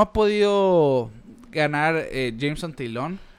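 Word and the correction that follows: Taillon: Taylon. – mejor dicho.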